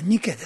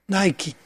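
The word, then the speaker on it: Nike: これ、まあ、言うまでもなく、もうお分かりだと、サモトラケのニケっていう、サモトラケっていうのは、島の名前で、ニケってのはその、ミケじゃないですよ、ミケ猫じゃないですよ、ニケですよ。このニケが英語で読むと、発音するとナイキっていう風になるわけです。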